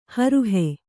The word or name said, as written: ಹೊರಹು